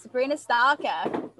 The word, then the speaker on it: Sabrina Stocker: That's how people say your name in America, right? – It is. Sabrina Stocker. I just said yes in the end.